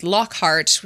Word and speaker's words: Lockhart: And then some fans have also noted that Lockhart, which is spelled L-O-C-K-H-A-R-T, sounds like Lockhart, H-E-A-R-T, you know, which maybe, you know, he locks up the brave hearts of others with his memory charms.